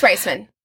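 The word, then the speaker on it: Raisman: Is it Reisman or Raceman? – I think it's I totally listened to a YouTube video today to figure out how to say her last name. I think it's Reisman.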